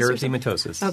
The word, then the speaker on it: erythematosus: erythematosus? – Erythematosus.